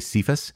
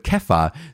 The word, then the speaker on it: Kepha: But Kepha sounds actually more close to what it would be called in Hebrew or Aramaic in those Mediterranean languages. And actually, there's an argument the pronunciation is Kepha.